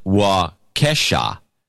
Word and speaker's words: Waukesha: Locals pronounce it Waukesha, no E as in P. So it's pronounced Waukesha.